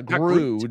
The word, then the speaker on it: Gru: Gru, no T. No T at the end.